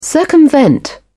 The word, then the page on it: circumvent: British English